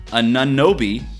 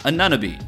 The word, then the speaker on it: Anunobi: Uh, Jim Bernstein tweets at me, dude, the pronunciation of OG OG's last name is Anunobi, not Anunobi. If you can't say it right, just call him OG.